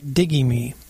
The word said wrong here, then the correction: Digi-Me: Digi. – So Digi, see?